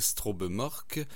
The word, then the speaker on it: Strobemørke: Oui, en effet, avec la formation Troïka, tirée de leur nouvel album, je vais le prononcer de la façon qu'on peut le lire en français, Strobemork, ou en norvégien, ça ressemblerait plutôt à Sontrobemerke.